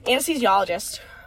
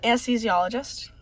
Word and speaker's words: anesthesiologist: Anesthesiologist. – You just nailed it. – Anesthesiologist. – That's how you say it.